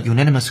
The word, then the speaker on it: unanimous: 那其中的 unanimous agreement 就是必须达成一致的意见。 unanimous 这个单词它以 u n 开头，但特别容易被同学们误读成 n 开头的声音，其实是不对的。